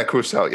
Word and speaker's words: Crucell: So there's this kind of informal get together between you and the three folks you mentioned, some folks from Crucell, which Am I pronouncing that correctly? Crusell? – Yeah, Crusell. Yes.